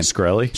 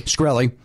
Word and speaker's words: Shkreli: How do you say this guy's name? – Shkreli. – Shkreli.